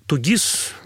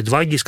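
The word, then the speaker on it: 2ГИС: Это Тугис или Двагис, как вообще, как правильно говорить даже? – Да кто как. Я даже слышала вариант Дубльгис. – Да, Дубльгис. На севере Дубльгис говорят.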